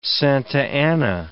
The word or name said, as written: Santa Ana